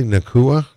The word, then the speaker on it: Nacua: Do you say Nacua? Is that? – Yeah. – Is that? – Yeah, that's officially, how they say it.